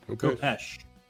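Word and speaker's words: khopesh: Am I saying that right? Kopeck? – Kopesh, I believe. – Kopesh. Thank you.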